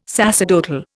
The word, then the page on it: sacerdotal: Indian